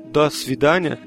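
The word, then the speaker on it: das vidania (до свидания): Assim como o spasiba, esse do das vidania, ele se escreve do, mas fala da, das vidania.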